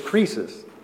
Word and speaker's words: krisis: But the Greek word translated as damnation or condemnation is krisis. We would spell it K-R-I-S-I-S, krisis.